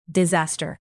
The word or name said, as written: disaster